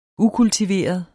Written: ukultiveret

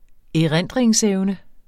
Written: erindringsevne

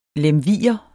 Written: lemviger

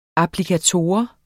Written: applikator

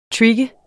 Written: trigge

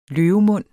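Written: LØVEMUND

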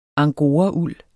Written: angorauld

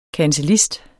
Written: kancellist

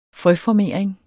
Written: frøformering

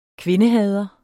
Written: kvindehader

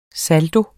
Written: SALDIENE

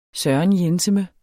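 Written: sørenjenseme

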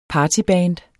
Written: partyband

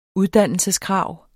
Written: uddannelseskrav

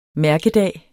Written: mærkedag

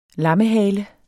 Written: lammehale

